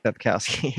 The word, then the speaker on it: Sapkowski: Sapkowski. – Sapkowski.